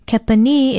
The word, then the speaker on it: kepani: Kepani is spelled K-E-P-A-N-I and pronounced kepani with a stress on the last vowel.